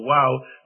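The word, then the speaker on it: waw: There's a little Hebrew letter, a W, we would pronounce it in English, called a wow,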